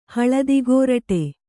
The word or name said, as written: ಹಳದಿಗೋರಟೆ